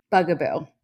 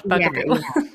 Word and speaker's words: Bugaboo: Bugaboo. – Okay. I know how it's spelled, but I don't know if I've ever said it out loud. Bugaboo.